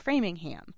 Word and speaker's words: Framingham: And I went to high school out in Framingham. – Mm-hmm. Yep, which is an authentic ham. – Yeah. – Is that the only ham? Because there's like Wareham, right? W-A-R-E-H-A-M, and like Needham, Dedham.